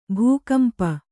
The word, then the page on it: ಭೂಕಂಪ: bhū kampa